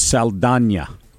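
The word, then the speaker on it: Saldaña: Because when they pronounced it last night, I saw in the clip I saw this morning, it was Saldana is how they pronounced her last name.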